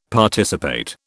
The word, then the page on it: Participate: British English